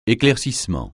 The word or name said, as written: éclaircissement